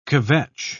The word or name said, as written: kvetch